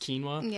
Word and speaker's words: quinoa: You've got quinoa, which is that funky one that starts with a Q and is still somehow pronounced quinoa.